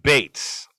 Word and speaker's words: Bates: tim bates rhymes with masturbates uh,